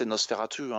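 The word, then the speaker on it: NOS4A2: Ça s'appelle NOS 4A2. Euh, mais qu'on peut prononcer Nosferatu hein, presque, euh, tellement le...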